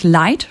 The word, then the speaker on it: Lighght: Also, der Albumtitel spricht sich Light, schreibt sich aber ungewöhnlich, nämlich L-I-G-H-G-H-T. Und ich habe ihn gefragt, warum ist das so? You're laughing about my... – G-H-G-H-T.